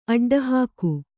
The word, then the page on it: ಅಡ್ಡಹೊಗು: aḍḍahōgu